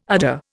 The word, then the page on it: udder: Indian